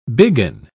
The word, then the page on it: biggin: British English